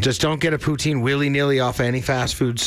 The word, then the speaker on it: poutine: Well, poutine, if you really want to say it right and not get everybody bent out of shape. But poutine is fine.